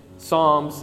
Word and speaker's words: Psalms: Again, if you're in here and you haven't been to church in a long time, you might be sitting there like, hey, why does this guy keep saying Psalms? It's got a P in front of there. Why aren't you pronouncing the P?